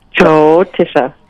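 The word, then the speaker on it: Jyotisha (ज्योतिष): Jyotisha.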